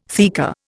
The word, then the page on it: theca: Indian